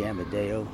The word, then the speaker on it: Amadeo: Gerlando Giuseppe Amadeo. So I simply read Joseph G. Amadeo. No one could pronounce it. Amadeo, a simple name, they call me Yamamoto, which is a Japanese admiral.